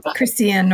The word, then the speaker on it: Christiane: No, just one additional thing that I read from, I realized that I read it some years ago from Christine Northrup. How do you say her name? – Christiane Northrup.